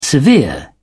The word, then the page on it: severe: British English